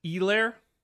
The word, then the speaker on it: Helaire: I believe that's pronounced Elaire. – What did I say? – Helaire. – No H. – Oh, okay. C-E-H. Clyde Edwards-Helaire. – It's basically like C-E-E, honestly.